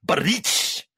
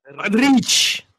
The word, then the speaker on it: Budrich: You didn't say it like him. Budrich. – Budrich. – Here's got to roll that R.